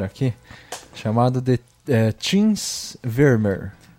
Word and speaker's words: Vermeer: E o V tem som parecido com um F, como no alemão. Então é parecido com Fermir.